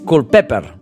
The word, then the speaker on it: Culpeper: Resulta que és un nom anglès normal i corrent. I el més curiós és que es pronuncia tal com ho hem dit, eh? Culpeper.